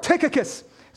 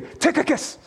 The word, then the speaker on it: Tychicus: Tychicus. You got to say it like that, too. Tychicus.